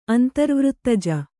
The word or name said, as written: ಅಂತರ್ವೃತ್ತಜ